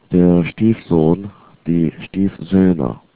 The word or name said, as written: Stiefsohn